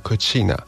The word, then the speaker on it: cucina: 今天是日本的意大利料理日，因为意大利的厨房这个单字发音叫做 cucina，而这 cucina 就有点类似日文发音的 917 这三个数字，所以日本他们也受到意大利的一些饮食文化的影响，他们就把 9 月 17 号定为意大利料理日。